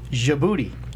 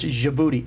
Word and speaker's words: Djibouti: Djibouti. – Djibouti? – Yes. – Who's booty? – It's spelled with a D, but it's pronounced Djibouti.